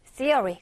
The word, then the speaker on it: theory: 발음 다시 이 여기가 우리 일명 번데기 발음이라고 하는 거 숫자 3할때 그거랑 같은 발음입니다. 그래서 theory.